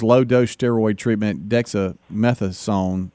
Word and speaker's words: dexamethasone: how do you say this? – It's dexamethasone.